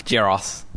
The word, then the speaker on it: Geros: Geros.